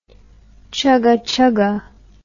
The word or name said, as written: chugachuga